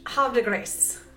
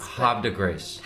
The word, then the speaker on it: Havre de Grace: Havre de Grace. – Havre de Grace. – I, I, don't know. I don't know how to do the R rolling.